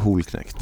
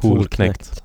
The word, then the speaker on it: Holknekt: Uh, hur uttalas det då? Långt O. Hol, uh. Holknekt. Holknekt.